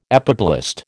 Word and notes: epiblast: American English

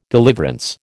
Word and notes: deliverance: American English

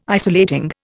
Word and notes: isolating: Indian

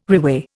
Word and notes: reweigh: Indian

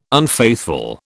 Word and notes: unfaithful: British English

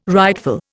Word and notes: rightful: Indian

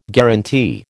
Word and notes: guaranty: American English

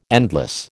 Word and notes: endless: American English

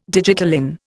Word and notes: digitalin: Indian

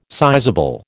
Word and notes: sizeable: American English

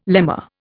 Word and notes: lemma: Indian

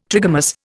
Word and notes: trigamous: Indian

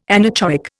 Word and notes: anechoic: Indian

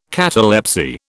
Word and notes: catalepsy: British English

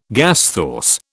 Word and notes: gasthaus: British English

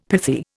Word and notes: pithy: Indian

